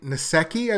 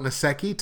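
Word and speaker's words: Nsekhe: Ty Nesekhe, I believe is how you pronounce it, N-S-E-K-H-E, I'm going to call it Nesekhe, Ty Nesekhe, injured his ankle.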